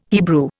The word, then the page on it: Hebrew: Indian